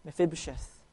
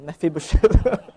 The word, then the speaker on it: Mephibosheth: Mephibosheth. I practiced that so I could pronounce it well. Mephibosheth.